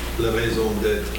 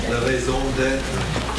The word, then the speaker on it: raison d'être: The French word, help me, I'm not French people, raison d'être. How do you say it? – La raison d'être. – Oh, that's good. Say it louder. – La raison d'être.